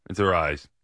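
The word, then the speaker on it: Arraez: They have the, I don't know how you pronounce it, Arias. – It's Arias. – Champion, but Arias, okay.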